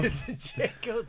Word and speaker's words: Django: Django, the D is silent.